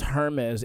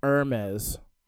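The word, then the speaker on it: Hermès: It's not actually pronounced Hermes. It's Hermes.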